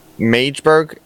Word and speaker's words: Magdeburg: From the multiple pastors from Magdeburg. Am I saying that right? – Yeah, I think it's right.